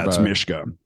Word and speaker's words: Mishka: The people have been calling me Mishka for 10 fucking years. So but how do you pronounce it? Mishka. – It's Mishka. You pronounce it with wake up energy, like, oh, Mishka.